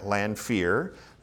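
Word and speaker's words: Lanphier: And the movement itself is attributed to a man named Jeremiah Lanfear. That's his name, that's how you say it.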